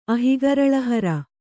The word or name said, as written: ಅಹಿಗರಳಹರ